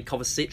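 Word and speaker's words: Kovačić: who told us that it was actually pronounced Mateo Kovacic, not Mateo Kovacic.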